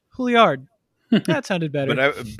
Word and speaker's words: Juilliard: Is that a weird way to pronounce Juilliard? I guess I've never really said it out loud because I'm not a class. – Yeah, that's how you say it.